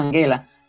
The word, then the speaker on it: Angela: en alemán esa G se pronuncia no como la de gestión, sino más bien como la de gato. Por eso, lo más cercano al alemán sería decir Angela Merkel.